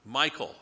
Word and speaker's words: Michal: Saul's daughter, Michal. That's how I would pronounce it, M-I-C-H-A-L, Michal, Michal. I'm not sure how she pronounced it, but she is not here to correct me, so I'm going to call her Michal.